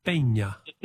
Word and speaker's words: Peña: With a little accent on the N, Peña.